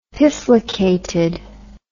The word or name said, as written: pifflicated